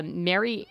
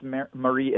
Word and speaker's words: Marie: Another complicated person in the book is a Portlander, Mary Equi. – I think it's pronounced Marie Equi.